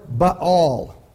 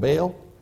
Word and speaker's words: Baal: which they prepared for Baal. You'd call it Baal. It's pronounced Baal, actually.